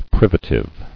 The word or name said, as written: Privatively